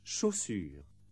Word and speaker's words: chaussure: First, CH. This is pronounced CH, chaussure. The combination TH is pronounced T, théâtre.